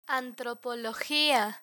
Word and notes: antropologia: spanish

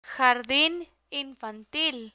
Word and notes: jardin infantil: Spanish

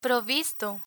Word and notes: provisto: Spanish

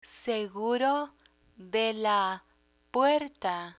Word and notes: seguro de la puerta: spanish

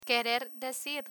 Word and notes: querer decir: Spanish

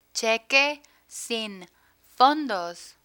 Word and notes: cheque sin fondos: spanish

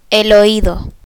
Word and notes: oido: spanish

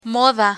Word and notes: moda: Spanish

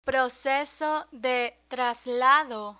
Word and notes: proceso de traslado: Spanish

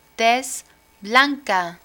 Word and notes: tez blanca: spanish

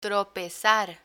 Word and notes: tropezar: spanish

